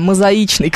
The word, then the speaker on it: мозаичный: Вы прилагательное образовали правильно, а ударение поставили неверно. Мозаичный.